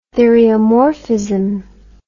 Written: theriomorphism

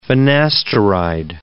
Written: finasteride

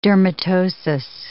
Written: dermatosis

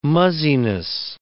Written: muzziness

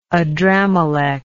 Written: adrammelech

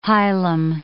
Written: hilum

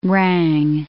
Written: rang

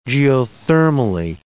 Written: geothermally

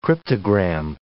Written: cryptogram

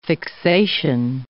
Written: fixation

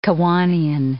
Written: Kiwanian

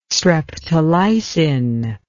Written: streptolysin

